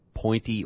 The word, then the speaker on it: Pointe: with the Champ Sports Pep Rally at Pointe Orlando. Okay. Oh, I bet that's Point because the E is silent. – Yeah, that's probably right.